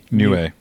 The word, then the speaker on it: Niue: I'm sure I'm pronouncing that wrong. N-I-U-E. – Niue. – Niue. – According to Wikipedia.